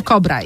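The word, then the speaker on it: Cobra: Istnieje zespół Kobra i on jest przez C rzeczywiście. I czyta się przez K, ale tutaj znaleźliśmy, więc wygrywa pani komplet nagród.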